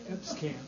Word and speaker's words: Emscamp: Upscamp. – Upscamp. E-M. – Can you spell it? – E-M-S-C-A-M-P. – E-P-S-A-M-P.